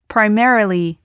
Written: primarily

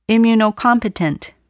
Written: immunocompetent

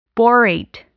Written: borate

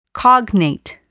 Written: cognate

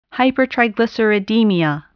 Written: hypertriglyceridemia

